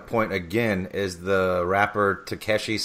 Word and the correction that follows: Tekashi: Six Nine. I don't even know if I'm saying that right. – Tekashi Six Nine.